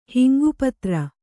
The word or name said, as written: ಹಿಂಗುಪತ್ರ